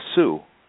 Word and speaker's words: Seaux: You mentioned another important person in this story, and I think his name is Sue, S-E-A-U-X. – Yes. – But he talks about the mountain lion controversy and his investigation and his experience on the trail. Tell us about who I maybe mispronounced as Sue. Explain. – No, they call him Sue.